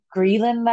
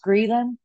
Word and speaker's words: ghrelin: And then they know in horses, horses that crib bite, crib biting is associated with higher um, ghrelin levels, I think it's pronounced ghrelin, G H R E L I N.